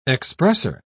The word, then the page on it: expresser: British English